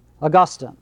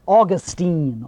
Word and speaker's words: Augustine: Augustine, not Augustine. Augustine is a city in Florida.